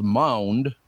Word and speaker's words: Maund: Eric Charles Mound M A U N D. Would you pronounce that mound? That's mound, isn't it? – Yeah.